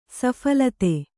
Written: ಸಫಲತೆ

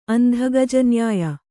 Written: ಅಂಧಗಜನ್ಯಾಯ

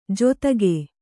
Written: ಜೊತ್ತಗೆ

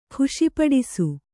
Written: ಖುಷಿಪಡಿಸು

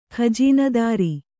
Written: ಖಜೀನದಾರಿ

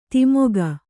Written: ಧಿಮಿಕು

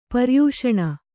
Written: ಪರ್ಯೂಷಣ

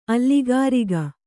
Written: ಅಲ್ಲಿಗಾರಿಗ

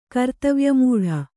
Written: ಕರ್ತವ್ಯಮೂಢೆ

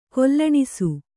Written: ಕೊಲ್ಲಣಿಸು

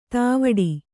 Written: ಧವಡ

